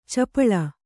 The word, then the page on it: ಚೆಪ್ಪಾಳೆ: ceppāḷe